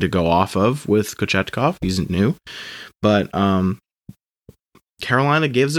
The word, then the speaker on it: Kochetkov: It's Pyotr Kochetkov comes in, the rookie from Russia, and he played very, very good.